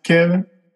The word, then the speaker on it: Kavin: This is Kevin Wilson. That's Kevin, spelled K A V I N, pronounced Kevin. So you can call me Kevin with an A.